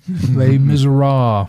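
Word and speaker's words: Les Misérables: So I'm going to get, how do you say that French novel? Is it Les Miserables? – Les Miserables.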